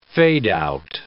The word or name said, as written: fade-out